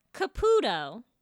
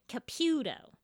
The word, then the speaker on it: Caputo: And it's not Caputo. Her family specifically pronounces it Caputo.